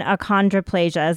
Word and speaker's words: achondroplasia: So you have the condition achondroplasia. Is that how you pronounce it? – Yes.